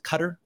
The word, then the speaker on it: Qatar: In Qatar, is it Qatar? Is that how you say it? – Yeah, Qatar, yeah, or Qatar, depending on where you're from.